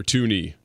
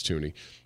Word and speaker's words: Thuney: By the way, Joe Thuney has been, or Tooney, not Thuney. It's spelled Thuney, but it's Tooney, has been declared out.